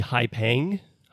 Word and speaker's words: Haipeng: Jing Haiping and Zhu Yongzhu. – I would say maybe Haipeng. Haipeng? – Yeah, that sounds better.